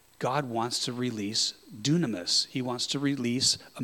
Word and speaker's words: dunamis: It is the word dunamis.